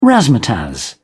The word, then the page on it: razzmatazz: British English